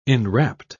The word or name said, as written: enrapt